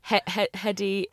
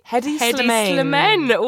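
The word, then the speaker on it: Hedi Slimane: Hedy Slimane. Hedy Slimane. Well, yeah. – The most incredible case of nominative determinism I have ever come across. – Absolutely. Hedy Slimane, as he is in my head. – Yeah, S-L-I-M-A-N-E.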